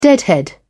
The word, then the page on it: deadhead: British English